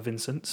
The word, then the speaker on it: Vincent: I am also a Vincent, so I know I'm pronouncing that correctly.